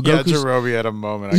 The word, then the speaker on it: Yajirobe: Yajirouji. I'm saying his name wrong, but cut off Yajirobe.